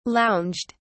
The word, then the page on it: lounged: British English